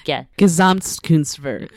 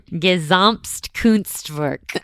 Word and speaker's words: Gesamtkunstwerk: Founded on the idea of creating a total work of art, I don't know how to say this. – Gesamtkunstwerk. – Perfect. Gesamtkunstwerk.